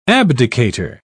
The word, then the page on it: abdicator: British English